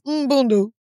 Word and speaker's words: Mbundu: If you're gonna make me say the M, it's Mbundu. But there's like no vowel between it, so I don't know how to say it.